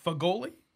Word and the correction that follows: fagioli: Fagioli. – Fagioli. Fagioli. Oh, gee, okay. – Pasta fazool.